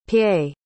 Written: pei

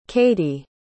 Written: cadee

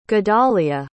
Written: gedaliah

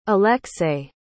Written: alexei

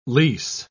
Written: lease

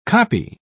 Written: copy

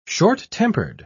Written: short-tempered

